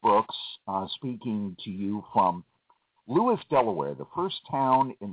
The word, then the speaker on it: Lewes: They spell it L-E-W-E-S. Some people who are not familiar with it will look at the map and say, oh, you must live in Lewis. Well, no, it's Lewis. Oh, L-E-W-I-S? No, L-E-W...